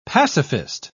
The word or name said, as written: pacifist